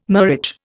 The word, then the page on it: murage: Indian